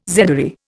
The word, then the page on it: zedoary: Indian